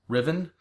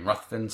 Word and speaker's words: Ruthven: But Polidori's story features a vampire character named Lord Ruthven. I've also heard it pronounced Riven, but I've always pronounced it in my brain Ruthven, so I'm going to go with that.